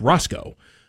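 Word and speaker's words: Racicot: Pretty prominent Republican back in the day was Mark Rassikot, who was actually pronounced Roscoe.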